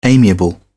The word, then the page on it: amiable: British English